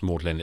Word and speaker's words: Magdalen: It's, It's spelt Magdalen, pronounced Maudlin.